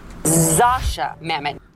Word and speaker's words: Zosia Mamet: So the correct way to pronounce Zosia Mamet is... – Zasha Mamet.